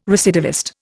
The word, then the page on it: recidivist: Indian